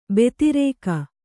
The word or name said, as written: ಭದ್ರಕ